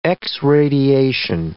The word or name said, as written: x-radiation